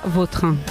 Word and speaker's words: Vautrin: Vauterin. Sí. Elegante. – La original francesa, Vautrin.